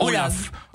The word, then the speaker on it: Olav: C'est sûr que la prononciation, Olav. – Oui, c'est Olav, ça s'écrit avec un V.